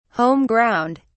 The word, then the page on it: home ground: British English